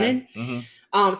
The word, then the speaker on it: Ganon: Was it Ganon? Is that how you say it? – Yeah, Ganon.